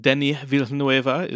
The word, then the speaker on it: Denis Villeneuve: And, Denis Villeneuve is definitely competent.